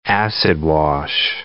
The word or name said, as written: acid-wash